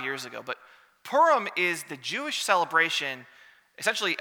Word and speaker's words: Purim: Purim, or Purim if you want to be, you know, hoity-toity and do broader pronunciation, but Purim is really what they call it.